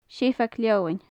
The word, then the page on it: seabhag-leomann: gaelic